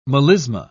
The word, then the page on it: melisma: British English